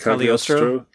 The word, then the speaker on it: Cagliostro: Cagliostro.